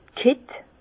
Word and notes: d' Kitt: Bas Rhin; Herrlisheim

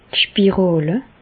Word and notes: d' Spiràle: Bas Rhin; Reichshoffen

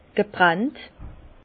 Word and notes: gebrannt: Alsatian; Bas-Rhin; Herrlisheim

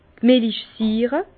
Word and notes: d' Millichsiire: Bas-Rhin; Herrlisheim

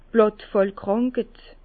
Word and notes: d' Blàttfàllkrànked: Bas Rhin; Herrlisheim

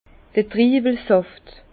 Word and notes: d'r Triiwelsàft: Bas Rhin; Herrlisheim

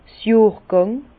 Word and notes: 's Johrgàng: Bas-Rhin; Herrlisheim

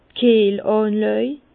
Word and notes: d' Kiehlànlàj: Bas-Rhin; Herrlisheim

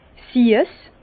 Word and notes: siess: Bas-Rhin; Herrlisheim

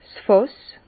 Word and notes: 's Fàss: Bas-Rhin; Herrlisheim

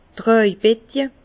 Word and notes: d' Tràjbetje: Bas Rhin; Herrlisheim

